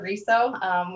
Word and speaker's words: RESO: It's an ongoing debate about how you say it, but I prefer RESO, which is also known as the Real Estate Standards Organization.